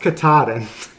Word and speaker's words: Katahdin: It's pronounced Katahdin. – I don't believe so. – It's I think the tallest mountain in Maine, and it's also the end of the Appalachian Trail.